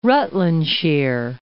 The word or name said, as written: Rutlandshire